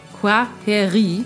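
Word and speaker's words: Kwaheri: Äh, Quaheri. Quaheri? Ja, genau. Das I spricht man länger hinten und das KW vorne wie ein Qua.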